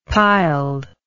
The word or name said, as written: piled